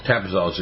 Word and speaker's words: Tapazole: How do you say that? – Tapazole, so yes.